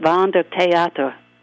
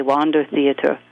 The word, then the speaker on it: Wander Theater: In German, we pronounced it Wander Theater. But here in America, I believe you say Wander Theater, and that was the traveling theater.